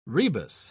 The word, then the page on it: rebus: British English